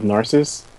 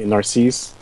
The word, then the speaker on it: Narcisse: So I have to tell you that within my own family, the pronunciation of our last name differs. I go with Narcisse. Other people say Narcisse. Like, I don't really care that much, but people like to be sticklers. If you are amongst them, feel free, but it's fine. I answer to both.